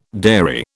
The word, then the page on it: dairy: British English